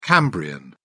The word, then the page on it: cambrian: British English